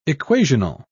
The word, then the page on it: equational: British English